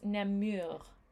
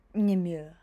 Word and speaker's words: Namur: It's this beautiful French, but it's Namur. – Namur.